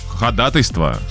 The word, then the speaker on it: ходатайство: Слово «ходатайство» правильно произносят в основном только юристы. Ну да, слово «договор». – Ходатайство. – Ходатайство. – Ходатайство. – Ходатайство. Да, правильно, ходатайство.